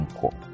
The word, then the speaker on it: 輪行: 輪行って読むのこれ。 – うん、和行じゃないの。 – 輪行、いやいや、輪行輪行。